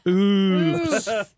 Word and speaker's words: OOOPS: That's Oops. – That's Oops. – No, that's Oops. You had to pronounce all the O's.